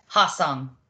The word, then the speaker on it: Hwaseong: which is Hwaseong. Right? Hwaseong? – Yeah. Hwaseong.